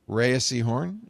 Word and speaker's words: Rhea Seehorn: Rhea Seehorn for Pluribus.